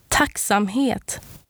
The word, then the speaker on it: tacksamhet: And the word this time is tacksamhet. And it means gratitude or thankfulness. And it's spelled T-A-C-K-S-A-M-H-E-T.